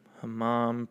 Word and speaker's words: hammam: Hammam pronunciation. Let's see if I was right. – Hammam. – Fuck yeah, hammam. So it's like you're saying, huh, mom? What'd you say, mom? Clean your room, huh, mom?